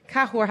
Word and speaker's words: Cahors: But again, totally opposite to what you, when you're going to order like an Argentinian Malbec, Cahors has a silent S at the end of it.